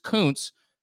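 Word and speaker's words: Kuncz: My last name is Koontz, which isn't that weird.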